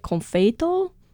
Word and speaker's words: Confeito: And the word comes from Portuguese and our theory, because in Japanese it's written as Compeito with a P sound, but then in the subtitles they write it Confeito. It seems that's closer to the Portuguese pronunciation.